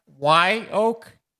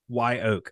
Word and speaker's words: Wye Oak: So I think it's pronounced Y-Oak. – Y-Oak, I think. – W-Y-E. Y-Oak. Okay.